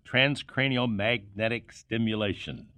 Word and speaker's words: Transcranial magnetic stimulation: Transcranial magnetic stimulation.